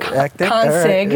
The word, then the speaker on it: Konsig: Sounds a little ethnic, but I like it. Konsig, right. Yeah.